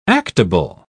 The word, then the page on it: actable: British English